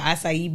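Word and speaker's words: açaí: Round of applause of you for saying that correctly. Because some people can't say acai. – Oh, what are you saying? Acai? – Acai. It's my ignorant friend like to say acai eat.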